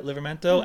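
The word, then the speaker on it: Livramento: And then Livermento, is that how you pronounce that? Livermento? Mm-hmm.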